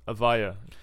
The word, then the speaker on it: Avaya: Avaya? – Avaya.